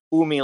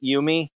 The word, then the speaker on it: Umi: Yeah, we learned that it's pronounced Umi, like you're saying, not Yumi.